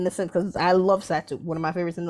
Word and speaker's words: Satou: So Hey man, hey man I, I, ain't been as tapped in, but is Satou Sat am I pronouncing that correctly? – Yes, Satou.